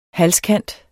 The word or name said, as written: halskant